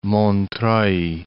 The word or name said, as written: Montreuil